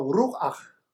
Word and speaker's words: Ruach: 风 Ruach Pneuma 在圣经里面被用来描述灵，希伯来文的发音叫 Ruach，希腊文的发音叫 Pneuma，我们翻译成灵，原来有另外两个含义，是灵也是风又是气。